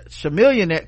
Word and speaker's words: Chamillionaire: Charlamillionaire, Chamillionaire, Chamillionaire, Damn, it's been so long since he was famous, I forgot how to pronounce Chameleonaire.